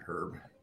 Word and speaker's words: Herb: I meant Herb. – Herb. Um, silent H. – It's uh French.